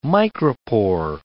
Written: micropore

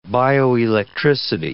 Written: bioelectricity

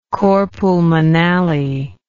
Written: cor pulmonale

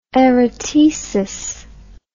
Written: erotesis

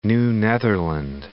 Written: New Netherland

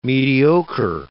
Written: mediocre

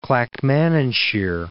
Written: Clackmannanshire

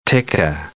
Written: tikka

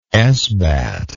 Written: esbat

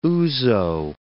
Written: ouzo

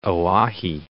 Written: Oahe Reservoir

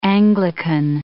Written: Anglican

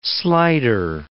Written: slider

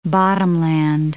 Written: bottomland

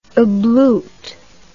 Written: ablute

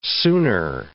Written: sooner

